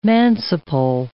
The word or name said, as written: manciple